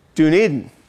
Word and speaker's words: Dunedin: Dan from another Dunedin, Florida. It's pronounced, oh, Dunedin. Dunedin.